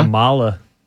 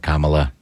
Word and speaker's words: Kamala: Kamala. – Oh, Kamala. Say it right.